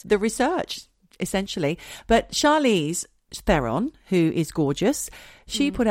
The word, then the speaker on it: Theron: I thought like Charlize Heron. Theron. – Charlize Theron.